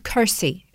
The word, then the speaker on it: Kursi: It's a place called Kursi. It's spelled K-U-R-S-I.